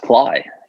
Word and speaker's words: Klay: It's pronounced Klay. – Yeah. Klay, there it is.